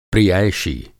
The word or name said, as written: प्रियैषी